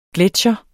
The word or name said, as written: gletsjer